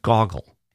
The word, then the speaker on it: Gogol: and say that Nabokov also wanted to correct the American pronunciation of Gogol and say it's not Gogol or Gogol, but Goggle.